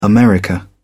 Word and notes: america: British English

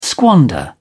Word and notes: squander: British English

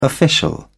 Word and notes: official: British English

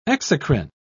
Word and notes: exocrine: British English

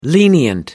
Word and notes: lenient: American English

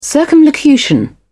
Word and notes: circumlocution: British English

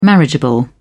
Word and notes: marriageable: British English

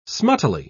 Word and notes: smuttily: British English

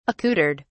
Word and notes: accoutred: British English

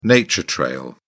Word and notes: nature trail: British English

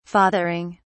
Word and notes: fathering: British English

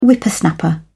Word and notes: whippersnapper: British English

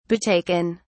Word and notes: betaken: British English

